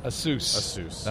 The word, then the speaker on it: Asus: I was told that to say it as it rhymes with Dr. Seuss. – Asus. That's what we're going to say from now on.